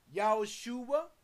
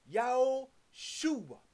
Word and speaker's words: Yahushua: Yahushua is four syllables. The accent on the third syllable, Yahushua.